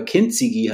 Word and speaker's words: Kintsugi: Ich kannte Kintsugi, da schreibt man Kintsugi, also mit U hinten. Aber Kintsugi heißt es eigentlich korrekt ausgesprochen.